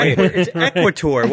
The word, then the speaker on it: equator: Yeah, the emphasis is on the KW. – On the equator. – It's equator. What are you guys talking about? You had the accent on the wrong syllable.